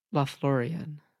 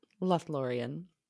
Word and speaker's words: Lothlórien: And the full version of that quote, he says, it is long and sad, for it tells how sorrow came upon, tell me how it's pronounced again. – Lothlórien. – Lothlórien.